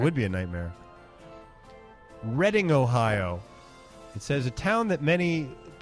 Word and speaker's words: Reading: Is it pronounced Reading, like Reading Railroad? – You know, maybe?